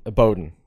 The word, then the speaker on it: Bowden: So he must Bowden. – Be what? Bowden. I know. – So let's get in that. It's spelled Bowden. It's pronounced Bowden.